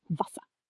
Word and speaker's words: Wasser: The letter W in German sounds like the English V. So Wasser, meaning water, is pronounced Wasser.